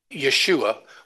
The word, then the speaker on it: Yeshua: It's could be Yoshua, it could be Yeshua, it could be a lot of different variations, but it all is pronounced Yeshua or something of that type.